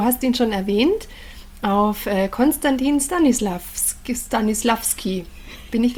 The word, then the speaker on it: Konstantin Stanislavski: Konstantin Stanislavski.